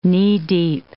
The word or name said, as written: knee-deep